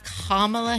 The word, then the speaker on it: Kamala: Mike Pence and, how do I say her name? Okay, like a comma. Kamala Harris.